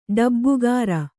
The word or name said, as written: ಡಬ್ಬುಗಾರ